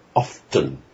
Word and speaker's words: often: Well, this privileging of writing over speech we still have today, for instance, pronouncing the T in often, often, because it's there in the spelling.